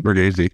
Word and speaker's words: Bargatze: Bargatze.